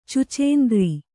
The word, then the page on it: ಛುಚ್ಚುಂದರ: chuccundara